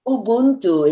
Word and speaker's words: Ubuntu: Ubuntu is spelled U-B-U-N-T-U.